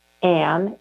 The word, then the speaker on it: Anne: Well, the first place is my website, which is spelled like my name, Martha Ann, A-N-N-E, Toll.